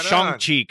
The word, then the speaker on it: Shang-Chi: Went and saw that Shang-Chi. – Oh, so did I. And it's Shang-Chi. Come on, man. Say it right. Don't be a dick. – Shang-Chi.